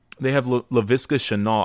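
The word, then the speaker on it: Laviska Shenault: They have LaVisca Chenault. I don't know if I'm saying that right. – That's correct.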